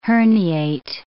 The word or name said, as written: herniate